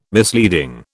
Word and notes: misleading: British English

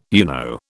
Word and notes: UNO: British English

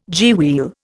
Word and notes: gearwheel: Indian

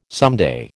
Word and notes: someday: American English

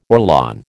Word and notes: Orlon: American English

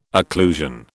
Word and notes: occlusion: British English

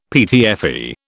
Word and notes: PTFE: British English

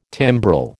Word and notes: timbrel: American English

